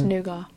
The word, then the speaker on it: nougat: It's nougat. – So she's pronounced it's nougat. The nugget is not acceptable.